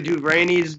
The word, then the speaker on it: DuVernay: Yeah, I mean, Ava DuVernay's doing that.